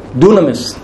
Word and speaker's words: dunamis: And the Greek word used there is the word dunamis. Dunamis. That's how it's pronounced.